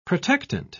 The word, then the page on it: protectant: British English